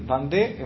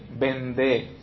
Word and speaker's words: Vendée: Vendée en francés es V corta E-N-D-E-E. Vendée, ¿no? Vendée con acento en la anteúltima E.